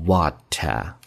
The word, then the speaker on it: water: Running water，美式发音读 water，英式发音读 water，所以这是区别啊。